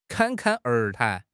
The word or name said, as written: 侃侃而谈